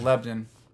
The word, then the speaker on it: Lebanon: If you're in Tennessee, you pronounce it Lebanon.